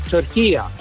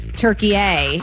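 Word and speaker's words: Türkiye: At the end is a short A. It's not a long A. In the Arabic language, it's called turkiya. So you could say turkiya. – You and I are making it a hard A. Turkey A, and it's turkiya.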